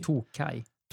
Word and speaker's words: Tokaji: Ingen aning om det är så man uttalar det, men det står Tokaji. Tokai. – Tokai. Tokai. Tokai och Tokaji. Det är väl två olika beroende på vad det är.